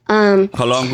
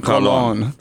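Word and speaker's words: Colón: Colon Guzman. – Oh, Jose Guzman Colon. – That's colon. – It. – Think about like your lower intestines, colon.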